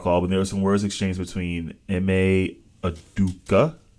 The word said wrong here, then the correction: Udoka: Yeah, Udoka. – Adoka. Mm-hmm. I always say his last name wrong.